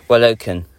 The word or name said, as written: Walokan